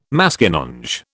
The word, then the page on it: Maskinonge: British English